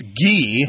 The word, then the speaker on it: Guy: If you're in France, this is going to be pronounced Guy.